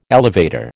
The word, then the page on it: elevator: American English